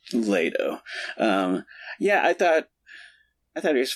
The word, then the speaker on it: Leto: Leto, I think it's pronounced.